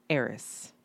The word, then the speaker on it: heiress: Heiress. Like with the H, the silent H.